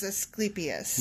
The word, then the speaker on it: Asclepius: Did you look up this pronunciation correct? – Yes, I looked it up, and okay. It is Asclepius.